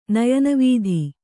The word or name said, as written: ನಯನವೀಧಿ